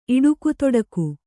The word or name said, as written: ಇಡುಗುತೊಡಗು